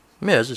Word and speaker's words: Ms.: Ms. is pretty much the dictionary standard pronunciation of the abbreviation MS period, which I also agree with, although I don't think people necessarily subscribe to that in common practice.